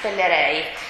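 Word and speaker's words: Pellerey: La competenza invece, avevamo visto la definizione, che non so come si pronuncia, Michele Pellerei o Pellerei,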